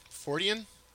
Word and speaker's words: Fortean: So this website you put together is singular for, let's see if I could say it, Fortean? – Yes, that's uh-huh. it. You're one of the few people who are not familiar with the word who get it right on the first try.